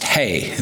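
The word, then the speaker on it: He: We're now on, it's spelled H-E, but it's pronounced Hey, the fifth letter of the Greek, of the Hebrew alphabet.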